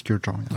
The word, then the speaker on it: Kurkdjian: Am I pronouncing it right? – You are. It's CEO and co-founder, actually, of Maison Francis Kurkdjian.